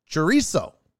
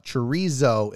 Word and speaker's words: chorizo: Chorizo Chorizo. See, I fucked it up. Chorizo is the most mispronounced food of all, counting 22 million listens.